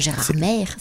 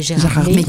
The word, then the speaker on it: Gérardmer: Et puis peut-être, on l'a fait l'année dernière, on avait été deux dimanches sur le marché de Noël de Gérardmer. J'espère que je l'ai bien prononcé. C'est pas Gérardmer, c'est Gérardmer.